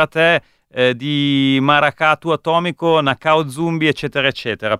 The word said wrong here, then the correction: Nação Zumbi: Maracatu Atomico, Nação Zumbi.